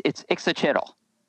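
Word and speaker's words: Ixitxachitl: For example, Ixit Chittle's got five syllables in the writing, but I only pronounce four of them. – So you say, it's your monster. So you say it's Ixit Chittle.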